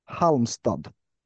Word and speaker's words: Halmstad: The Halmstad. – Who? – Halmstad.